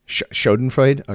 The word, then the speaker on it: Schadenfreude: If it's the Schadenfreude, or is that how you say it? Schadenfreude. I'm not German. – Schadenfreude.